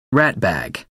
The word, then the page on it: ratbag: American English